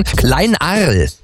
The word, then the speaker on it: Kleinarl: Am Telefon ist jetzt Ralf aus Kleinarl, oder? Habe ich das richtig ausgesprochen? Kleinarl.